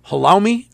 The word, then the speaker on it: halloumi: I think it's called halloumi. H-A-L-L-O-U-M-I. Am I pronouncing that right? – Yes. Yeah.